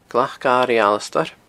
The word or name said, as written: glacairighalasdair